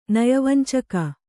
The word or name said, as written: ನಯವಂಚಕ